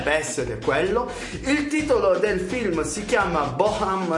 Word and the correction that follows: Bohemian: Rhapsody, spero che si dice così. Come si dice, Ricky? – Dica Bohemian lei.